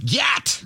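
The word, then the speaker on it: gyat: Gayat!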